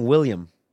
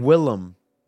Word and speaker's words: Willem: Why do you keep saying William? – What is his name? – It's Willem.